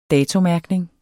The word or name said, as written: forfald